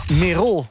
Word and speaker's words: Merol: Die maakt met André Hazes en Merol, heb ik geleerd dat je moet uitspreken...